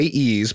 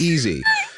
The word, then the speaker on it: EASE: They're calling it an industry-first Elastic Application Security Edge, EASE, which you'd say ease, but no, the announcement says that's pronounced easy. Fine, easy. Okay, easy, but even though it says ease, fine.